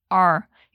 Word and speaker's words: our: Ou então, naquela pronúncia que eu falei que é mais reduzida do our, se diz are.